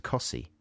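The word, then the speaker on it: Costessey: There's another place very near Wymondham that's spelt Costessi, and it's pronounced Cossi.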